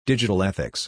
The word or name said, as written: Digital Ethics